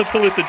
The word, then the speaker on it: Talitha: Is Talitha Jay there? – No. – Give it up for Talitha Jay. How are you? – Good, I'm good. I'm impressed you guys are still saying the name right.